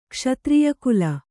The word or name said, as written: ಕ್ಷತ್ರಿಯಕುಲ